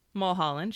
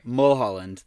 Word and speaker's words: Mulholland: Mulholland, sure. – Mulholland, thank you.